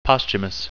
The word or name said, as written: posthumous